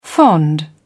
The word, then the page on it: fond: British English